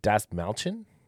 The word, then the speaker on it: Dastmalchian: David Dastmalchian? – Yes, that sounds right.